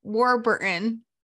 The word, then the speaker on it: Warburton: It's Patrick Warbutton? – I'm sorry, Warburton. – Okay. Patrick, I can go with. Warbutton? – Kirsten, W-A-R-B-U-R-T-O-N. Warburton. Warburton. – Patrick Warburton.